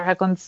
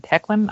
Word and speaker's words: Hoechlin: Is it Hoechlin or Hoechlin? Hoechlin? I don't know how to say it. – I've always said Hoechlin. – I think it's Hoechlin. – Hoechlin, sure. Sounds legit. Him.